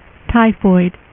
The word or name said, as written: typhoid mary